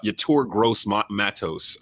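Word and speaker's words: Yetur Gross-Matos: I don't know if I pronounce his name right, to be honest with you. Uh, let me make sure I pronounce his name right. Uh, Yator Gross Matos uh, out of Penn State.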